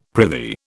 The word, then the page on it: prithee: British English